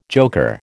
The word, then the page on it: joker: American English